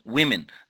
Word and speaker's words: women: طبعا لما نقول the position of women in Islam، كلمة woman امرأة والجمع women في النطق، woman women the position of women in Islam، ونعرف إنه Islam honors the woman، الإسلام يكرم المرأة.